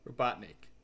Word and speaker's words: Robotnik: Stop saying Robot Nick. – It's not Robot Nick. It's Robot Nick. – No, I'm looking at this. It's Robot and then Nick. N-I-K, that's how it's spelled.